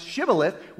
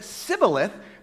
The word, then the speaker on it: Shibboleth: The Gileadites pronounced Shibboleth with the SH sound. The Ephraimites didn't. So here's what Judges records. They said, all right, say Shibboleth. Now, if he said Shibboleth, Sibboleth, because he couldn't pronounce the word correctly, they seized him and killed them at the fords of the Jordan.